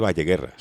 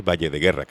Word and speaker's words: Valle de Guerra: No se dice Valle Guerra, se dice Valle de Guerra, que a los valleros les molesta.